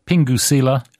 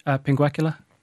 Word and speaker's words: pinguecula: Pingucilla? – Pinguecula.